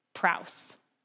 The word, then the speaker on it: praus: And you know, the Greek word for humble, this is hilarious, is actually praus funny that it rhymes with mouse.